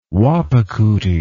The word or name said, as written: Wahpekute